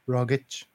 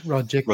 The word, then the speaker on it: Rogic: pronouncing his name Rogic as opposed to Rogic or Rogic or some other variation.